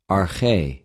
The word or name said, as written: ἀρχη